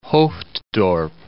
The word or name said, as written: Hoofddorp